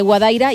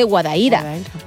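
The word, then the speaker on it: Guadaíra: Por ejemplo, decimos Alcalá de Guadaira y es de Guadaira.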